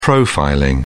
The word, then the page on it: profiling: British English